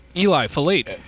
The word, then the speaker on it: Eliphalet: How do you pronounce that? – Eli Philate.